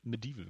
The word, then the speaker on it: Medieval: Und weißt was, du, nimm einfach mal äh, Medieval, äh, Medieval wird es ja ausgesprochen, ja.